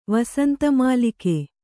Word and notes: ವಸಂತಮಾಲಿಕೆ: vasanta mālike

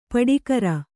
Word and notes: ಪಡಿಕರ: paḍikara

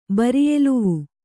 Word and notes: ಬರಿಯೆಲುವು: bariyeluvu

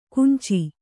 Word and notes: ಕಾಂಚಿ: kānci

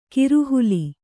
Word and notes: ಕೋರೆಹಲ್ಲು: kōre hallu

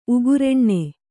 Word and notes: ಉಗ್ರಾಣ: ugrāṇa

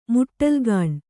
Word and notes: ಮುಟ್ಟಲುಗಾಣು: muṭṭalugāṇu

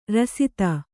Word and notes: ರಾಸ್ತಿ: Kannada

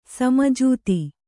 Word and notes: ಸಮಜಾತಿ: sama jāti

